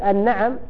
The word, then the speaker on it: النعم: إذن هذا من الأخطاء الشائعة أنه يقال حمر النعم، كذلك النعم بفتح النون، المراد به إيش؟ النعم، المراد به الإبل والبقر والغنم، وأكثر ما يطلق على الإبل. والمراد بحمر النعم أشرف الأموال عند العرب، فقد ينطقها بعض الناس النعم، هذا خطأ، فمن يتغير المعنى يتغير المعنى تماما.